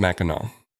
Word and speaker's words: Mackinac: No, there's an AC there. – It's pronounced Mackinac. – I don't understand. – You know your neighbor state, Connecticut? – No.